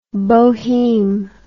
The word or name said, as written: boheme